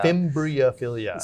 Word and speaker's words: Fimbriophilia: Starting with Eophilia and Fibrophilia. Did I pronounce that? Or Fimbriophilia. Fimbriophilia.